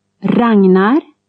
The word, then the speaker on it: Ragnar: GN after a vowel is like NG plus N. – Ragnar.